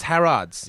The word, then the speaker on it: Harrods: Oh, it's pronounced Harrods. – Yes, it is. Okay.